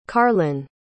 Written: karlyn

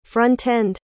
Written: front-end

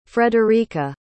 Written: friederike

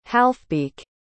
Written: halfbeak

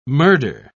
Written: murder

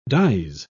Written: dies

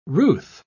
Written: ruth

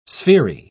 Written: sphery

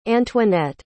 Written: antoinette

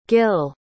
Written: gil

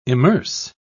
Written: immerse

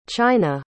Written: chynna

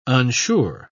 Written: unsure

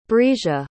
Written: brisia

